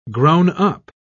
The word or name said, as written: grown-up